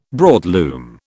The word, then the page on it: broadloom: British English